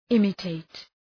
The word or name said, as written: imitate